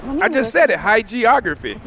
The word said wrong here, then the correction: hagiography: Hagiography, according to Google.